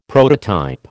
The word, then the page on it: prototype: American English